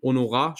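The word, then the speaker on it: Honorat: Honorar schließe ich mir jetzt mal an. Ich denke, das Haar spricht man nicht. Stummes Haar wie im Französischen.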